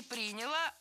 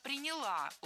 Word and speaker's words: приняла: Была создана особая комиссия, которая и приняла вот в работе эту систему. – Не приняла, а приняла. Ударение в этом глаголе ставим на окончание.